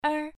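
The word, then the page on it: er: tone 1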